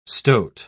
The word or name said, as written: stoat